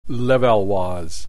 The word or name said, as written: Levallois